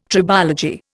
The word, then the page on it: tribology: Indian